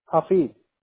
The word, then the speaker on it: Hafid: Hafid.